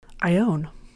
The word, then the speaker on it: Ione: Ione. – How do you spell that? – I-O-N-E.